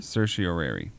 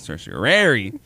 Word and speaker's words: Certiorari: Certiorari. That's what it's called. – Certiorari.